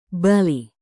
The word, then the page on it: bali: indonesian